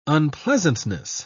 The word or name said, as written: unpleasantness